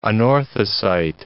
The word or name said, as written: anorthosite